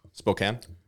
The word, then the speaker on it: Spokane: How do you say it? – Spokane. – It's spelt Spokane, which is dumb. – Spokane. – It's Spokane.